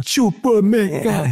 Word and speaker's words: Chukwuemeka: Wir können ihn nicht aussprechen. – Dafür bin ich ja da. – Ja, aber dann müssen wir irgendwie im Stadion auch irgendwas anderes machen, oder? – Chukwuemeka. Ja, ja.